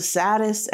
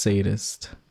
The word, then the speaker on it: sadist: But in the show, one of the main characters has, he's a sadist and a masochist, I believe. And his wife, are you going to watch the show? – No, but I think it's okay. Pronounced sadist. – Sadist, okay.